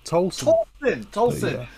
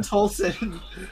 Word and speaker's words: Towlson: Am I saying that right, John? – Yeah, Towlson. Towlson. – Yeah. Jon Towlson. Yeah, there's no E in there to make it sound like towel.